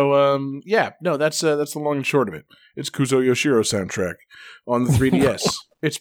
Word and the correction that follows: Yuzo Koshiro: So we got two fantastic songs from Etrian Odyssey 4 and composer Yuzo Koshiro. Want to make sure we say it right at least once.